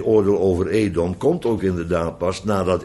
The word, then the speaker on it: Edom: Edom is eigenlijk gewoon Adam, dat weet u, dat is dezelfde naam. Men heeft besloten dat het hier moet uitgesproken worden als Edom, omdat het inderdaad over Esau en de Edomieten zou gaan.